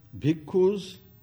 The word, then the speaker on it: bhikkhu: And Buddha said to them, Bhikkhus, bhikkhu means monk. It is a Sanskrit word. Sanskrit word, actual word is bhikshu, which has been in Pali been pronounced as bhikkhu.